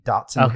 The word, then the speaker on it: Dachshund: You know, one of the Dachshund hound dogs, the long dogs that they call wiener dogs, that has lived to be 20 years old.